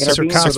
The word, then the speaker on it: Cercospora: Yes, this year we had what we believe was, I'm not sure if to pronounce it, Cercospora. – Yep, Cercospora.